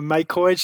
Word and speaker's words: MicroEJ: Comment est-ce qu'on prononce correctement MicroAge alors oui, effectivement, euh, MicroEdge se prononce, enfin s'écrit micro e euh, et effectivement, euh, on l'appelle MicroEdge, version anglaise, parce qu'on joue un peu sur les mots.